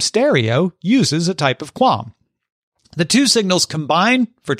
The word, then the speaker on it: QAM: Wi-Fi 7 will also increase capacity and reduce latency thanks to increased quadrature amplitude modulation, which I am assured is pronounced QAM, even though there's absolutely no U in QAM.